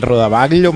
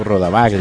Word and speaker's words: rodaballo: El rodaballo me gusta mucho. – No, rodaballo. – Ah, pero es que yo ya lo pronuncio así. Digo Rodabaglia.